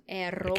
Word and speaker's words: Aromatica: Roll your R's. Aromatica.